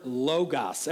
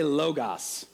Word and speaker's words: logos: Everyone say logos. Logos. Logos. If you spell that in English, it's L-O-G-O or logo. You say it differently to signal that you know a little bit of Greek. But logos. So a logo is a thing that you see that means another thing.